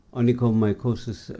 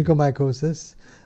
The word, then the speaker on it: onychomycosis: Look, in practice, which I see very, very commonly and very, very poorly treated, onychomycosis, I use the correct term of pronunciation. – Yeah, onychomycosis.